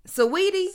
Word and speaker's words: Saweetie: Listen, I have been calling her Sweetie, and somebody at work was like, "I hate how she pronounces her name." I was like, "Yeah, Sweetie," and nobody's correcting. Oh, shit. Saweetie.